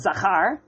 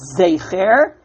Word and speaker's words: Zecher: I remember being taught it says Zachar. And they go through a whole razzle-dazzle here. What exactly is the correct pronunciation? And the Gemara goes on. They go to the gentleman who knows Diktuk well. And he says it actually says Zecher.